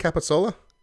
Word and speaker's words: capicola: It is a capicola. Is that how you say it? Capicola? – Capicola. – Capicola. C-A-P-I-C-O-L-A. So it's literally cap, I, and then cola.